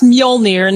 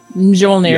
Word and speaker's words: Mjolnir: Yeah, just like it's Mjolnir, not Mjolnir.